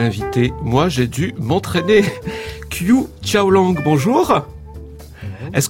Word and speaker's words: Qiu Xiaolong: The Chinese way is Chou Xiaolong. – Chou Xiaolong. – Ça, c'est la prononciation chinoise.